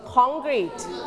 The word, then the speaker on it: concrete: มันเป็นคำที่ยืมมาใช้เพราะฉะนั้นตัว N ตัวนี้ออกเสียงเป็นเงอะนะคะเสียงเงอะ Concrete